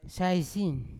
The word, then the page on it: saisin: Yawa